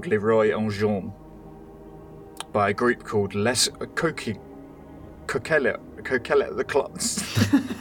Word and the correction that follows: Les Coquelicots: I really fucked that up. I'll message you it. – Nice, thanks. – Les Coquelicots. Coquelicots. It's, yeah. That's pronunciation for you.